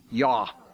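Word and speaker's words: ja: I belong to Joggers Anonymous, J-A, pronounced in Norwegian, yaw.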